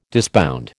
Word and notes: disbound: American English

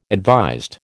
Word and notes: advised: American English

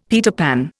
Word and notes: Peter Pan: Indian

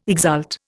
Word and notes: exult: Indian

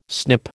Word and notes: snip: American English

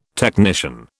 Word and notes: technician: British English

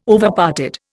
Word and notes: Overparted: Indian

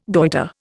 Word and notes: goitre: Indian

